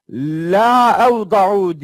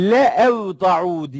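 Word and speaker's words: Le evda'u (لَأَوْضَعُوا): Orada La evda'u diye yazılır. Le evda'u diye okunur. Yani açıp bakabilirsiniz.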